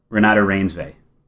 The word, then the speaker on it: Renata Reinsve: Renata Reinsve.